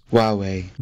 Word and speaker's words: Huawei: It is also sometimes difficult to pronounce. – Huawei. – That is what it is. Huawei.